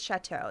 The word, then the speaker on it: Shatto: However, when they came over through Ellis Island, they changed Chateau, the original spelling, to S-H-A-T-T-O. So people really? – Say Chateau, we say it's Chateau. That's the correct pronunciation.